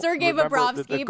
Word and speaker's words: Sergei Bobrovsky: Sergio Bobchuk. I mean, is that how you say his name? I don't. – Sergei Bobrovsky, but I like the way you said Bobrovsky.